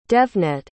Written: devnet

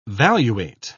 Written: valuate

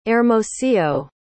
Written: Hermosillo

